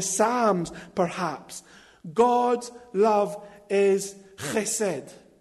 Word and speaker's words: chesed: Right, I'm no specialist at Hebrew, but I think it's pronounced chesed.